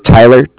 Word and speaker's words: Tyler: My name is Tyler, T-Y-L-E-R. And I am calling with Cardiff. It's spelled C-A-R-D-I-F-F, as in Frank Frank.